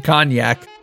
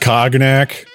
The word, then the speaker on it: cognac: I do wonder why they call it an orgy, though, Winchester asked between glasses of cognac. – It's pronounced cognac.